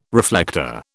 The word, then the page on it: reflector: British English